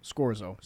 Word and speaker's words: Scorzo: Scorzo.